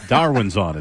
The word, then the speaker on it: Darwinson Hernandez: And he turned to Maz and he said, how would you pronounce D-Z? What is it? – Darwinson. I would say Darwinson is how you say it, right, man? – Darwinson Hernandez, yeah.